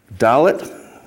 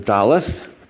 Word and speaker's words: Dalet: Let's read Psalm 119, Dalet. Or Daleth. One is modern Hebrew pronunciation, the other is ancient Hebrew pronunciation.